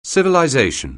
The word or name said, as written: civilization